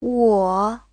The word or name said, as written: wo3